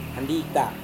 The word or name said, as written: andita